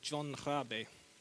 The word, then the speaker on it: Rabe: To begin with, I'd like to introduce you to a very interesting historical figure called John Rabe. Anya tells me that's roughly the correct pronunciation.